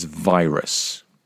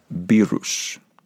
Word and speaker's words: virus: And the final word is virus. Don't be tempted to pronounce that virus. Virus.